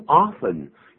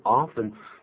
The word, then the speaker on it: often: 这个 often, 好像有人不是念成 often, 他是这样念 often. – Some people say often, I say often. – 两个都可以，对不对啊？ – That's right.